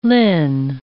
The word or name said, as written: Lin